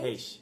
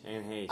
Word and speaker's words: Heche: Is it Heche or Heche? How do you say it? – Heche. Anne Heche.